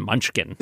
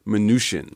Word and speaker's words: Mnuchin: And I can tell you that officially the pronunciation is not Mnuchin, it's Mnuchin. – Like minutia. – Mm. As a wonderful email to Kai Rizdal once put it, the CH is soft as in champagne or charlatan.